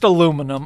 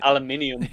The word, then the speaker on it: aluminium: Okay, it's pronounced aluminum. Okay, can we just agree on that? – Aluminium.